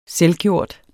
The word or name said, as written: selvgjort